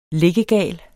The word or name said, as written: liggegal